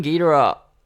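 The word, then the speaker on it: Geedorah: Yeah, Ghidorah, Ghidorah, is that how you say it? – Ghidorah. It's said so many times in different ways on the album itself, and it pisses me off.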